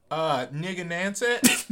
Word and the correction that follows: Narragansett: Narragansett, made on honor, sold on merit.